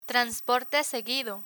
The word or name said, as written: transporte seguido